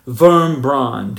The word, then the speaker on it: Wurmbrand: I read the book by a Romanian pastor named Richard Wurmbrand, W-R-M-B-R-A-N-D, Richard Wurmbrand, or probably pronounced Wurmbrand correctly.